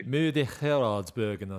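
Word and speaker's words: Muur van Geraardsbergen: You pronounce it better than I do. – Murder Gardersbergen, I think. – Yep, that's exactly what I was going to say.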